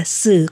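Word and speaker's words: xử (死): có âm đọc rất giống với chữ tử trong tiếng Trung đọc là xử có nghĩa là chết.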